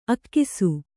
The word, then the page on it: ಅಗುಸೆ: aguse